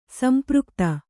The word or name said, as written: ಸಂಪೃಕ್ತ